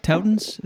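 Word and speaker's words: Touton: Am I saying that right? Towtons? Is that it? – Yeah, have a Towton or do it out.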